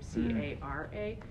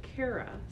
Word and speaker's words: Cara: I had a social studies teacher who uh, couldn't spell my name, couldn't remember how to spell my name, C A R A. So he and pronounce it Cara. So he always pronounced spelt it C A R E A. So he could say Cara.